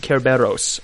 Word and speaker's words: Kerberos: It comes from the Greek Kerberos. Mm-hmm. I'm definitely mispronouncing that because I don't know from Greek.